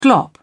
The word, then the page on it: glop: British English